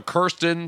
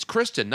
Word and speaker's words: Kristen: Yeah, but this one has what I love about this actress in it is that no one can get her name right. Some people call her Kirsten, some people call her Kirsten, some people call her Kristen. It's Kristen Stewart, right? – Yes. – It's Kristen, not Kirsten.